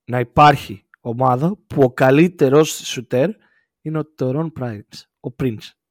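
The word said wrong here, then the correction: Taurean: Τόριαν Prince.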